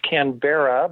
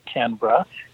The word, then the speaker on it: Canberra: Looks like it should be pronounced Canberra, but everyone there pronounces it Canberra.